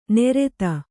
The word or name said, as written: ನೆರೆತ